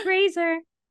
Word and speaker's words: Fraser: Rhymes with Fraser. – Fraser. Yes. Yeah.